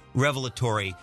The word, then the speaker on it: revelatory: But before we go to the break, I want to say it's pronounced revelatory. You, sir, were quite incorrect.